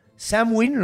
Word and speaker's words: Samhain: Samhain, lo estoy diciendo así porque así se pronuncia, pero se escribe Samhain.